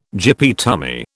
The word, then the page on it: gyppy tummy: British English